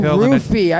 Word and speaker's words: roofie: What is it? Roofing? – Well, when you roofie someone, you're dropping a pill. Oh, roofie. I said roofing.